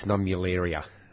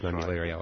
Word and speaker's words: Nummularia: And Roger, Roger got it right. It's Nummularia. Atriplex Nummularia.